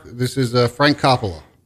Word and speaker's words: Coppola: I know it's pronounced Coppola because I've talked to him on the phone.